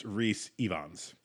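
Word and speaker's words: Rhys Ifans: By the way, his name is pronounced Reese Evans. – Evans. Okay. Oh, perfect. – And now we know. – Now we know. So Kurt Connors, which is... – Because he's Welsh.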